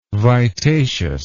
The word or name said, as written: vitaceous